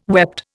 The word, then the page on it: wept: Indian